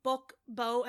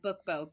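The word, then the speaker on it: BookBeau: And just so people know, it's spelled Bookbo as in B-E-A-U, right? – Correct. Yeah, Bookbo, B-E-A-U.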